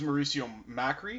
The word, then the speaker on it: Mauricio Macri: And this is Mauricio Macri.